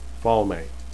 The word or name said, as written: falme